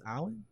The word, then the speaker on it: Allyn: So it's I think it's Allen. It's spelled Allyn.